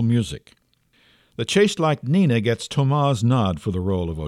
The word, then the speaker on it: Thomas: The one chosen thus will ascend to that coveted pinnacle of the dance world from which Beth has just been dethroned by Thomas, which is, by the way, pronounced Thomas.